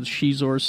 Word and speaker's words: Xizor: So I think we're going to call this episode Xizor's Cigar. – Yeah. – That's the winner. – Yeah, it's the Mandarin X is pronounced with a sh.